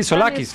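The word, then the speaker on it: Tzolakis: Tosolokis. Me complica. – Tosodolis. ¿Cómo se vería diciendo esto, no? Es Tosolakis, ¿no? Sí, Solakis. – Solakis, porque la T y es Solakis.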